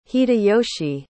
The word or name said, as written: hideyoshi